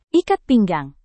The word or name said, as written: ikat pinggang